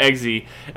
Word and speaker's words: Eggsy: Eggsy. Yeah, Eggsy.